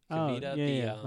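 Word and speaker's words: KeVita: Kavita, like the kombucha.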